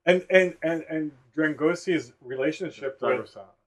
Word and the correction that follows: Dragosani: Dragosani's. – Dragosani's.